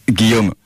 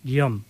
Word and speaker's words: Guillaume: Me vas a perdonar, yo te llamo en castellano, Guillermo, pero ¿cómo se dice tu nombre realmente en francés? – Guillaume.